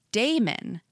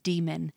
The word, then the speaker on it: daemon: I read it as Damon, D-A-E-M-O-N, as Damon, like the A-E sound, and it is a conscious effort to say demon, which is how the pronunciation is in, you know, the audiobook and the fandom and everything like that.